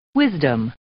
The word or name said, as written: wisdom